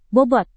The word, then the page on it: bobot: Indonesian